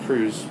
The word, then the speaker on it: Cruz: Carter, and then Cruz, like a boat cruise.